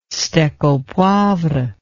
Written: steak au poivre

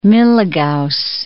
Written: milligauss

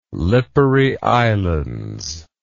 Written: Lipari Islands

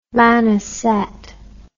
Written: Lanaset

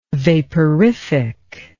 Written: vaporific

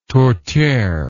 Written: tourtiere

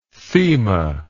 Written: thema

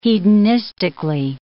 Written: hedonistically

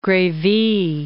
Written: Grevy

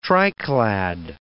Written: triclad

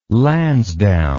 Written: lansdowne

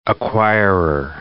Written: acquirer

